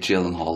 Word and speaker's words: Gyllenhaal: Jake Gyllenhaal, that's what Rebecca said. Yeah. Would you agree? – Well, she's Swedish, so I would trust her, her pronunciation. – Yes. – He's like, her and Daniel, our director, they're both Swedish, so I would trust their pronunciation better than mine. And mine's just a bastardized American version of the purity that is Sweden. – Yes, so we leave it with Jake Gyllenhaal, like always.